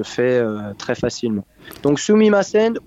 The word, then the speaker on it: Sumimasen: vous leur dites Sumimasen. – Sumimasen. Et euh, Sumimasen, voilà, donc euh, quatre syllabes, hein, Sumimasen.